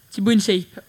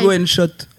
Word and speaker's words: Thibaut InShape: Thibaut InShape. – Ouais, Thibaut InShape. – Thibaut InShot. Parce que pour la petite anecdote, tout à l'heure, j'ai prononcé Thibaut InShot, donc ils m'ont tous charrié là-dedans.